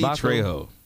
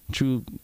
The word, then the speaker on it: Trejo: Danny Trabajo. Trejo.